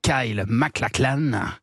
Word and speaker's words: Kyle MacLachlan: L'acteur Kyle MacLachlan.